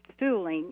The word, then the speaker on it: Suhling: It says Vic Sulling, if I have the pronunciation correct, S-U-H-L-I-N-G, Vic's sign, Gas for Less. So that must have been prominent to people happy to find a place where they could tank up there all those years ago. How did you manage to build this sign, or was it rescued? – Okay, the property where our museum used to house Vic Sulling, is how you pronounce it, gas station.